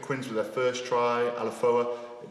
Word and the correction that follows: Alofa: looked like it was... – Alofa, you mean Alofa, Alofa? – Alofa.